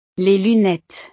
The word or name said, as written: les lunettes